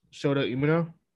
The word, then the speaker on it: Shota Umino: Versus Eddie Kingston, Wheeler Yuta, and Shuta Uno? How do you pronounce this nigga name? – Shota Umino? – Umino, yeah.